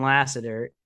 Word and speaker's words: Lasseter: And then when you look at John Lasseter, I'm sorry if I pronounced that Lassiter, yeah. – incorrectly, John Lasseter.